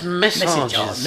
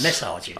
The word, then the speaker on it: Messages: This is Orchestral Maneuvers in the Dark and Massages. Is that right? – Well, close, but a different pronunciation. – Oh, is it? – Yes. – Oh, it's Messages. – Messages.